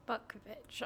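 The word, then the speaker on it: Butkovich: So we just had the murder of John Bukovich. Am I saying that correctly? – Bukovich, I'm pretty sure.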